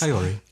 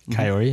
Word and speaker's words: Kaori: Kaori. – Kaori, mm-hmm.